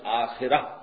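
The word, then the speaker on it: آخرة: عاجلہ آخرہ کے مقابلے میں، آخرہ آخرت جسے ہم آخرت کہتے ہیں، عربی میں اس کا تلفظ آخرہ ہوگا، اس آخرہ کے مقابلے میں دو الفاظ آتے ہیں.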